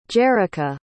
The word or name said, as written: jerrica